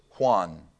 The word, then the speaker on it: Juan: If you hear me pronounce something, all right, I'll pronounce this, Juan. How do you spell that? W-H-A-N? You spell it J-U-A-N, but J-U-N is Juan.